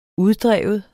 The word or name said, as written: uddrive